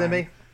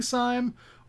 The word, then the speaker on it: Syme: I want to start by talking about the protagonist of this novel, Gabriel Syme. Syme. I don't know how you guys pronounced it in your head. I say Syme. – Simi. Simi Gabriel.